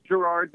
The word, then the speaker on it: Gerard: It, it, it, it's Gerard, but you know what? I've, I've heard it said both ways, and I never fault I, anyone I, for how they pronounce no, it.